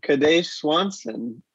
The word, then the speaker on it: Kadesh Swanson: As John already mentioned, we are going to be meeting with a man who makes tobacco pipes, Kadesh Swanson.